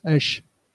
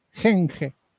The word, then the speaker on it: henge: No es Edge. Henge.